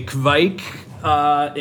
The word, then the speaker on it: Kveik: I say Kveik. – Okay. – And try to like say the K really quickly and go into that.